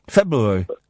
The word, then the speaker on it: February: February. You say you can't say February very well either. The implication being, I can't say it well. I say it properly. I pronounce the first of the two R's.